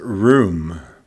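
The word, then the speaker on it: room (רום): The word extol is a word in Hebrew that is pronounced room. It's R-U-M, but it's pronounced like R-O-O-M. It's room.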